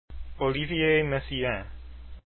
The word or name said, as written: Messiaen